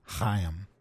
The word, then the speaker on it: Chaim: Chaim. – Silent C on Chaim. – Last name Witt. – W-I-T.